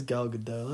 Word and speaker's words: Gal Gadot: Fucking, who says Gal Gadot? That's not her name. – It's for sure Gal Gadot.